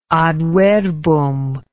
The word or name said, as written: adverbum